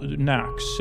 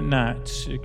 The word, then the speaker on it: Knocks: Well, Knox, not Knots, not Don Knotts, and not K Knotts and Wood, and not Knotts, Knox. I'm sorry, I don't always pronounce K-N-O-C-K-S.